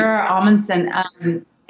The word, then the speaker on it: Omundson: Also inspired casting with Timothy Omundson. Am I saying his name right? – You sure, Omundson.